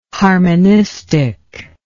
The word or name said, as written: harmonistic